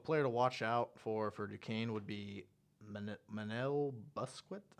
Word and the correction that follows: Busquets: I hope I'm saying that right. – Manel Busquets.